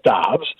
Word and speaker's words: Doubs: who is listed as being pronounced Doubs. So I'm going with Doubs. I know he's told us actually both things, but the Packers officially list him as Doubs. So I'm going Doubs.